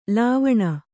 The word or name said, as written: ಲಾವಣ